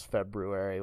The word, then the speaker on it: February: Yeah, I also kind of feel like you just wanted to show off that you can pronounce February with the R in there.